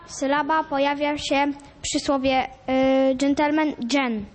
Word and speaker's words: gentleman: Kochani, ja mówię gentleman. To jest słowo pochodzące z języka francuskiego. Gdzie pada akcent? Zwróćcie uwagę, w słowniku poprawnej polszczyzny jest zapisana wytłuszczona sylaba. Wytłuszczona sylaba oznacza, gdzie akcentujemy to słowo.